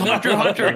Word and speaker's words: Hunter x Hunter: It's Hunter, Hunter. You don't pronounce the X.